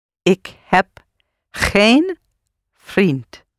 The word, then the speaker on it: geen: And to say, I have not a friend, not any friend, or no friend, you say geen. So een is one and geen is not one. Geen, not any or no. Okay? And it's spelled G-E-E-N, just een with a G in front of it. Geen.